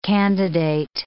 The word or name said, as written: candidate